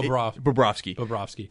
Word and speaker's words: Bobrovsky: I mean, outside of Barkovsky, it's Tkachuk. You look at even Bobrovsky.